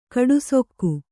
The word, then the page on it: ಕಡುಸೊಕ್ಕು: kaḍusokku